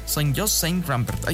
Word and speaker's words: Saint-Just-Saint-Rembert: A ver, el decreto busca prohibir los mosquitos tigre en el municipio de Saint-Jean-Just-Rament, ubicado en la región de Ródano-Alpes, en el departamento de Loira. No sé si lo dije bien. Saint-Just-Saint-Rembert, ahí está.